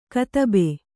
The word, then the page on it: ಕದಬೆ: kadabe